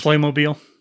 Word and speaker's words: Playmobil: Playmobil.